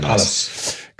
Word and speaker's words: Palas: Paulos. – Okay. Very nice. – Palace.